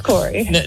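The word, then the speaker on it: Cory: No, my name's Corey.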